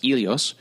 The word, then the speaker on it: Helios: for the Greek god Helios, or in Greek it's pronounced Helios, helium is the dominating element of our sun.